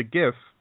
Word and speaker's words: GIF: And then I go back to the GIF. I pronounce it with a hard G, guys, because the G stands for graphic.